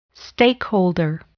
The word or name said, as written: stakeholder